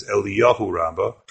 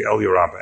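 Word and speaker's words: Eliyah Rabbah: Some people mispronounce it as Eliyahu Rabbah, but that wasn't his name. It was the Elyur Rabbah.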